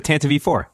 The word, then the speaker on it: Tantive IV: Wait, how did you guys pronounce the name of this ship? – It's the Tantive IV. – Is that how you pronounce it? – That's how I always pronounce it.